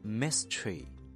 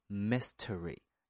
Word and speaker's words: mystery: 那么有关这个单词还有一个名词形式 mystery，这是英音，美音读作 mystery，意思是神秘事件。我们最后不要忘了它的拼写 M-Y-S-T-E-R-Y， mystery， mystery。下一个单词 desire， D-E-S-I-R-E Desire，渴望，希望。